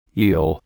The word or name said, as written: eel